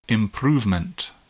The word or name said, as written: improvement